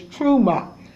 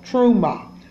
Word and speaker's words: T'ruma: The name of the Torah portion 19 is Truma, and it's spelled T, capital T, hyphen R-U-M-A, and it's called Truma.